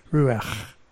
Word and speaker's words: ruach: Now, if you want to spit out the window while you're driving and you're a Hebrew, you could do it well with this word, ruach, because that lasts a little bit. That's how you say it.